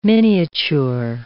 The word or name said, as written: miniature